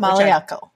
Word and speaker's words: Maliakel: I think that's they they showed us how to phonetically pronounce it. Molly I, Uncle.